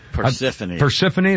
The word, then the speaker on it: Persephone: Is it Persephone or Persephone? – Persephone. – That's it, because they spell it. You know, it's like who's the other... Who's the other female character that ends in Persephone, something Persephone and spelled like phone?